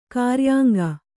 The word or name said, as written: ಕ್ರಿಯಾಂಗ